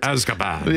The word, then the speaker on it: Azkaban: Azkaban.